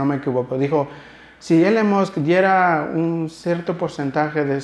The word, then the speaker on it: Elon Musk: La semana pasada Nuevamente dijeron que Elon Musk, creo que lo pronuncié bien, ¿verdad? Ah, yes. Eh, Elon Musk es un hombre, uno de los hombres más ricos de nuestro planeta Tierra.